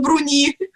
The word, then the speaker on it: Бруни: И не Федор Иванович Бруни, да, мы уже выяснили, что это Бруни, он Бруни. – Да, Федор Иванович Бруни,